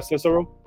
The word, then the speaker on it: Cicero: Like Cicero? – Yeah, Cicero.